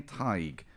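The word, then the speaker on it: Tadhg: It's the name Tig, which is a brilliant name, but I need to break it to a lot of people called Tig you're spelling your own name wrong. It's T A D H G.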